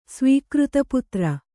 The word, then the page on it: ಸ್ವೀಕೃತಪುತ್ರ: svīkřta putra